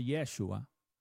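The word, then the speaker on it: Yeshua (ישוע): Pero el efecto no, porque este Jesús de Nazaret, que en hebreo se llama Yeshua, o Yeshua, es el mismo que está limpiándote de tus pecados ahora, aunque en español se llame Jesús.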